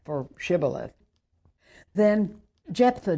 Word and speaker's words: Shibboleth: Then they would say to him, 'Then pronounce the word Shibboleth.' And he would say, 'Sibboleth,' for he could not pronounce it."